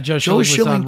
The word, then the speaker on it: Schilling: I'm saying his name right? – Yeah, Joe Schilling, right? – Absolutely, yeah.